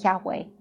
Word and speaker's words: Yahweh: So Yahweh is Y-H-W-H. And what they did was they put the vowels in there for Adonai to pronounce it. And by doing that, they come up with Jehovah. So that's how you come up with Jehovah, but you can also pronounce it Yahweh.